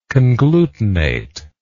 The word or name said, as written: conglutinative